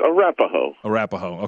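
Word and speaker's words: Arapahoe: Some of the details, and this is from the, I think I'm pronouncing this correctly, Arapaho Sheriff County deputies. – Oh, come on. John, it's Arapaho.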